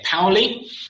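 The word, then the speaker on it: Paoli: And then we've got, correct my pronunciation here, Powley. Correct.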